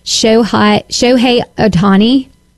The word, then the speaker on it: Shohei Ohtani: John Smoltz, Justin Verlander, Jacob deGrom, Shohei Ohtani. Did I say that right? – Yeah.